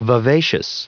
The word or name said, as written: vivacious